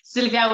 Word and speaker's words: Sylwia: Yeah, you pronounce it differently when you speak. Uh, in in Ireland, we say Sylvia. – Yes, yes, and it's Sylwia with W. Maybe that's why.